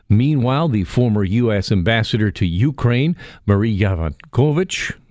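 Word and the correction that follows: Yovanovitch: I think I mispronounced that, Yavanovich, pardon me, says she was devastated when she learned that President Donald Trump wanted to remove her from her post.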